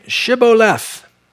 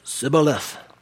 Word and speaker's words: Shibboleth: If he said no, then they would say to him, "Then say Shibboleth." And he would say, "Sibboleth." He couldn't pronounce his SHs.